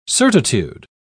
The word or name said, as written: certitude